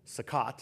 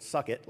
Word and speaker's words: Sukkot: And one of the festivals, the one in fall, Septemberish time, is called, I pronounced it wrong last time, it's called Sukkot. I think I called it Suck It last time, but it's Sukkot. I don't even know if that's exactly how you pronounce it, but better than Suck It.